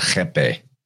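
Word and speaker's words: rapé: Well, it's spelled like they spell it R-A-P-E, but they pronounce it Jepe.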